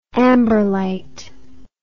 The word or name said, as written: amberlite